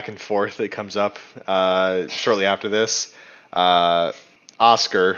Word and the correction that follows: Osgur: who is, I just pronounced that like Oscar, like the, goodness gracious. – You did, and I hope you keep it. – Osgur, who is the orc they've captured.